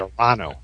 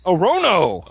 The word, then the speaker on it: Orono: I always said Orano. – Orano? – I'm sure that's wrong, but that's what I would say. hey, if they don't send us pronunciation guides, I'm going to pronounce it however I want. – It's like Orinoco Flow, baby. – O-R-O-N-O. That's Orono.